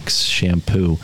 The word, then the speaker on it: Champoux: But yeah, it's spelled C-H-A-M-P-O-U-X, Shampoo.